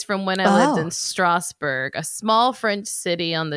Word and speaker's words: Strasbourg: Well, it's spelled Strasbourg. – So then that's French. – Strasbourg. – Strasbourg. That's how you pronounce it in French. – Strasbourg.